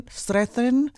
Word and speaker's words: Strathairn: So the she you know one lady plays the mother and then the other one plays her daughter, and I don't want to butcher his name here. David David Strathairn Strathairn. – I believe you're saying it right.